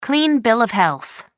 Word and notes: clean bill of health: American English

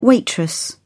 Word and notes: waitress: British English

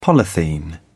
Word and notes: polythene: British English

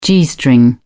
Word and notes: g string: British English

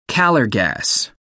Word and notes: calor gas: American English

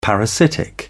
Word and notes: parasitic: British English

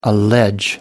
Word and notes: allege: American English

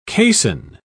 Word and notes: caisson: British English

